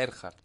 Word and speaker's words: Earhart: Gerhardt, perdón, por la e-, pronunciación. Emilia Earhart.